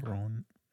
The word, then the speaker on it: Theron: Theron.